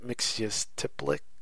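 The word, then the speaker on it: Mxyzptlk: On Earth 1, Superman's foe from the fifth dimensional land of Zerf is Mr. Mixyez Pitalik. – On Earth 2, Superman's foe from the fifth dimensional land of Zerf is Mr. Mixyez Pitalik, which is spelled MXYZTPLK. – Yeah, like, what's that? Mixyez Pitalik?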